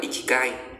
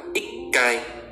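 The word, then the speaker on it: ikai (一階): bình thường sẽ đọc là ikigai nhưng người nhật người ta không đọc như thế thì người ta làm nào người ta bỏ chữ thứ hai của số đi bỏ chữ chi đi sau đó người ta cộng với âm ngắt vậy chúng ta sẽ đọc là ikai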